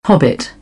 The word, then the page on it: hobbit: British English; ɒ